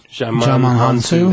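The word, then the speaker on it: Djimon Hounsou: African dude, Jamon Hansu.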